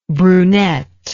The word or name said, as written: brunetness